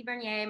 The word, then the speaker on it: Vernier: You guys can follow me on all social media platforms at Missy Bernier. My last name is spelled V-E-R-N-I-E-R.